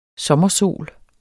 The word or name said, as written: sommersol